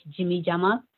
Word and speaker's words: Djimidjama: Jimmy Jama, which is spelled as D-J-I-M-I-D-J-A-M-A. The D is a silent, so we call it Jimmy Jama.